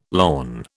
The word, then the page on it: lorn: British English